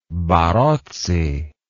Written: barozzi

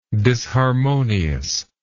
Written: disharmoniously

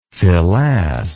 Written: filasse

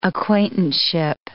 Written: acquaintanceship

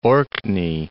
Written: Orkney Islands

